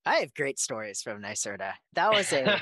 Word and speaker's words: NYSERDA: I know it's NYSERDA.